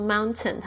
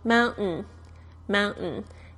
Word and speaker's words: mountain: 因为这个 t 呢，其实在美式发音里面呢是有一个潜规则的，就是听 t 如果发呃放在这个 n 的发音的前面呢，它有时候是弱化，完全不说出来的。那如果说呢，现在你有很仔细呢，在听发音或者是在看美剧的时候，你就会发现老外呢说这个 mountain，他们不说 mountain，他们常会说 mountain，mountain，甚至呢，比如说像我们要说国际化，就可以说成 international，international，他们就不说 international，甚至呢，像这个列印机、打印机是这个 printer，常常就会听到他们说成。